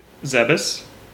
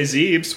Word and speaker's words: Zebes: I used to say Zeebs when it was a stage in Smash. – I was going to say, I said Zeebs until it became canonically, phonetically, everyone else said Zebes.